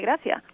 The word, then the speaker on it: gracias: Apart from saying gracias, we will not pronounce the final S, so we will say gracias. – Ah. – Buenos días, which would be like good morning, buenos días, we would say buenos días.